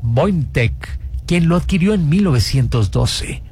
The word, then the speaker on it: Voynich: Y también tenemos por otro lado este misterio, este caso sobre el manuscrito Voynich, así se pronuncia.